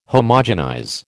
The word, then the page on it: Homogenize: American English